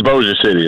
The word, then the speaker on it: Bossier City: It's Bossier City. That's how you pronounce it. Oh, man, I tried to get cute with the old French thing.